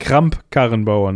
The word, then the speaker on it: Kramp-Karrenbauer: Annegret Kamp-Karrenbauer. Ja, sie ist auch versagt. Kramp-Karrenbauer, ne? – Ja.